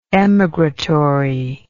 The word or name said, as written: emigratory